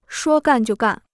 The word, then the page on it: 说干就干: shuō gàn jiù gàn